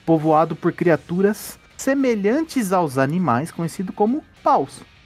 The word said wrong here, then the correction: Pal: É que a pronúncia, né? A gente tem essa dificuldade de pronunciar o L e o U, né? É tudo pronúncia de U, mas é pal. Né? Tem que dar aquela abridinha na boca para falar.